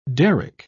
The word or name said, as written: derrick